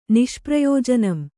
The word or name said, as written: ನಿಷ್ಪ್ರಯೋಜನಂ